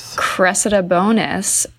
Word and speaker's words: Cressida Bonas: Cressida Bonas, Harry's ex, was also at the ceremony.